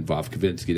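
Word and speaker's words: Vovkovinskiy: I think that's correct, man. Vovkovinsky. That's how I would pronounce it. So if it's wrong, I can't make fun of you. – Okay. – Because that's pretty much how I would say it.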